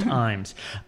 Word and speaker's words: Ijames: The first one I'm going to talk about is Fat Ham, written by James Imes, but it's spelled James I. James. – Oh. – But I. James, I-J-A-M-E-S, is pronounced Imes.